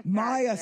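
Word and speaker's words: Maja: Maha Stark. I think, is that pronounced correct, Maha? – Maya Stark.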